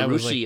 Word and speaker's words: Russia: There's a Rushi, like, Ohio. It's spelled Russia.